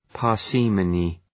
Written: parsimony

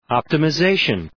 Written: optimization

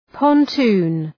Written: pontoon

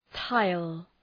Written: tile

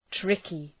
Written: tricky

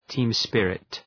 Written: team spirit

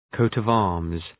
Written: coat of arms

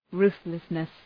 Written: ruthlessness

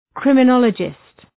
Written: criminologist

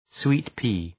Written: sweet-pea

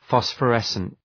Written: phosphorescent

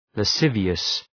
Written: lascivious

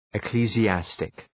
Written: ecclesiastic

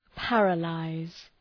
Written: paralyse

